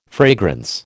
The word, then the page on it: fragrance: American English